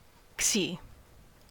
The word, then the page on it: xi: American English